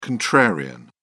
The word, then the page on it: contrarian: British English